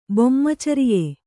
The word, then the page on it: ಬೊಮ್ಮಚರಿಯೆ: bommacariye